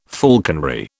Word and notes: falconry: British English

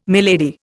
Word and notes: milady: Indian

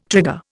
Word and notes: trigger: Indian

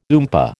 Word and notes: oompah: American English

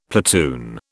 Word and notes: platoon: British English

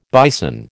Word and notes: bison: American English

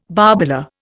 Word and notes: barbola: Indian

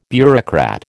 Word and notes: bureaucrat: American English

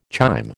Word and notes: chyme: American English

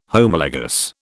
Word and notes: Homologous: British English

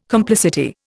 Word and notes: complicity: Indian

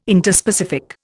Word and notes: Interspecific: Indian

